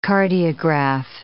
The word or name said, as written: cardiograph